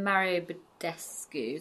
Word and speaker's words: Mario Badescu: I use the Mario Badescu, is that how you say it? – Yes.